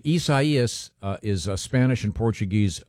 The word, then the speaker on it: Isaias: Just to make it's like Osiasis. – That's very, very close. It's Hurricane Isaias. Isaias.